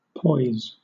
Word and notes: poise: British English

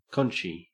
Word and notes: conchy: Australian English

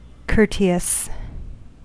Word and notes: courteous: American English

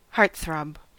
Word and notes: heartthrob: American English

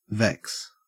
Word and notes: vex: Australian English

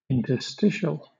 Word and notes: interstitial: British English

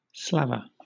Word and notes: slaver: British English

